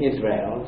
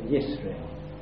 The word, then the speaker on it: Yisrael: So instead of saying Israel, as we would say, they would say Yisrael.